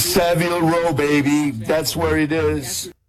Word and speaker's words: Savile: There's a proper way, because the way that it's spelled, there's one L, so it's Savile. If it's Seville, as you had suggested incorrectly, we might add, it's Seville.